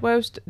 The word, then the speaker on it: Woeste: Yeah, and I'm pronouncing it Woest. W-O-E-S-T-E. – That sounds right.